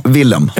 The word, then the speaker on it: Willem: Man säger alltid fel. Willem Dafoe. – Willem Dafoe. Eller Willem eller Willem Dafoe. – Willem. – Ja. – Mm. Alltså uttalat på svenska i stort sett.